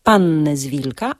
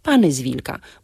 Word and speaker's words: Panny z Wilka: Czyli mówimy panny z wilka, a nie pany z wilka.